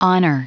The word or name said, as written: honor